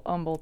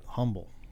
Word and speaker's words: Humble: And you know, we don't pronounce the H in Humble. – Correct. – Yeah. That's how you know if it's an outsider. – Well, I was born in Midland. We have a street in Midland named Humble. – Mm-hmm. – And most everybody in Midland pronounces it Humble. – Yeah. – And I was like, well, that's not the way. That's not the way that's said at all.